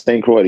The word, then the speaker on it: St. Croix: Yes, St. Croix, the U.S.